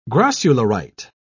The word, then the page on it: grossularite: British English